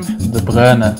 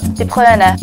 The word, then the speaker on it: De Bruyne: Wait, how do you actually say his name? I think I've been getting it wrong all this time. – De Bruyne. – De Bruyne.